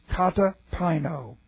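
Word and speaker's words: Katapino (καταπίνω): And the word is katapino. Katapino. This is a compound, K-A-T-A-P-I-N, long O. Katapino.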